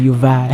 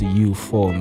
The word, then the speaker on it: eu-IV: Let's take it back to when we used to call them U-Vi. – Hey, U-Vi, yeah, you know, but we got the pronunciation right, man. It's pronounced U-4, man.